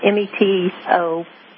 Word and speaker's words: metoprolol: One is clonidine, one is hydrochloride, one is metrolol. I can't pronounce it. Metoprolol. – It's called metoprolol.